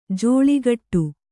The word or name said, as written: ಜೋಳಿಗಟ್ಟು